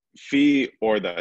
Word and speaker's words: Fiorda: So Fiorda. It's Italian, actually.